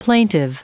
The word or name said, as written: plaintive